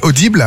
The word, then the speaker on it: Audible: Le concept est signé Audible. Alors, c'est comme ça qu'on le prononce en français, donc je vais le dire comme ça.